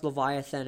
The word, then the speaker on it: Livyatan: And it's and it's spelled differently, but it's pronounced Leviathan, as in the Leviathan Sea monster.